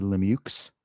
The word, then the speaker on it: Lemieux: It's actually Lemieux. Please say Lemieux.